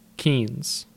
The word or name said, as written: Keens